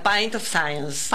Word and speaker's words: Pint of Science: Gabriela me dice, vale, pronunciarlo como quieras, bastante decente, me pone en paréntesis, pero bueno, le pifié, era Pint of Science.